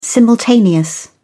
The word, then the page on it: simultaneous: British English